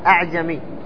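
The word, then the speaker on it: أأعجمي: الهمزة الثانية تكون مسهلة مثل كلمة أعجمي، كم فيها لحفظ؟ فيها التسهيل. كيف التسهيل؟ أنك لا تقول أعجمي ولا تقول أعجمي، يعني لا تنطقها ألف ولا تنطقها كذلك همزة، وإنما تجعلها بين بين، فتنطق بالهمزة الأولى محققة وهي همزة استفهام، أعجمي وعربي، فلا تقول أعجمي، هذا تحقيق.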